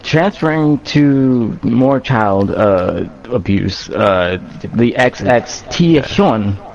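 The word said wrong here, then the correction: XXXTentacion: I don't know how to fucking pronounce this fucking Triple word. X Tentacion. – Tentacion, there you go.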